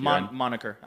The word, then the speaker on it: moniker: And it's controversial to say this because people don't like the esports monkier when it comes to the fighting game community. – Is that how you pronounce that word? Is it moniker or monkey? – Moniker. I'm pretty sure it's moniker.